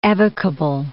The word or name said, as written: evocable